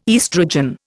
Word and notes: estrogen: Indian

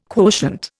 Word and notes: quotient: Indian

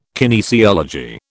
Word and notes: kinesiology: British English

